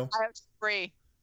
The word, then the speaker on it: Io Shirai: With the recent rumors, and it's no longer a rumor, of Io Shirai. Is that how you say her name? Io? – Io Shirai. – Cherie, okay.